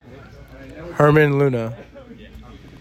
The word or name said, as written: Herman Luna